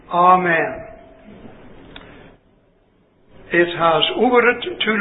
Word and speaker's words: amen: And that means, according to this particular Bible, which I believe is correct, the first letter is pronounced as in A-H, ah, as in father, F-A-T-H-E-R. So that the correct pronunciation of the word is unquestionably amen.